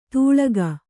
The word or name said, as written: ತೂಳಗ